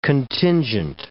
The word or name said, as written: contingent